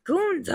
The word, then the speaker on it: Gunther: But then there's the there's the single Gunther Riddle you're saying it wrong. – Gunta. Gunta. Sorry.